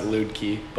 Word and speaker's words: Luedtke: I pronounce it Luedtke, but I don't mind if people pronounce it Luedtke.